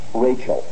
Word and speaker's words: Rachel: There's only one exception in the Bible, and that's Rachel. There, the CH is soft. It's not Rachel.